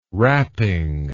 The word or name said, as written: rapping